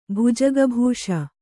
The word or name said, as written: ಭುಜಗಭೂಷ